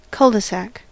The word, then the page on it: cul-de-sac: [ˈkʌl.də.ˌsæk]; /ˌkʌl.də.ˈsæk/; /ˌkʊl.də.ˈsæk/; /ˈkʊl.də.ˌsæk/